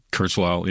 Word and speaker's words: Kurzweil: Ray Kurzweil, is that how you say his name? – Kurzweil, yeah.